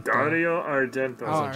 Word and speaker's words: Dario Argento: Dario Argentino? – Dario Argento.